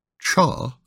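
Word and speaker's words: cha (茶): This written character, which is pronounced as cha, symbolized the way tea brought humankind into balance with nature for the Chinese culture.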